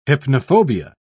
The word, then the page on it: hypnophobia: British English